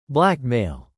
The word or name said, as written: blackmail